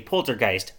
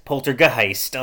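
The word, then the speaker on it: Polter(g)heist: Um, so this is great because everyone asked me how do I pronounce this game because it's spelt P O L T E R parentheses G close parentheses H E I S T. So I say poltergeist, poltergeist. Like it, it doesn't matter.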